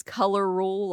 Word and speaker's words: Color Rule: So am I saying it's Color Rule? Like, am I saying it correctly? – Yes, right?